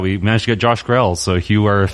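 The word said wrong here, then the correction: Grelle: I believe it's pronounced Greeley. – Greeley? – Yes.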